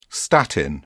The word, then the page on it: statin: British English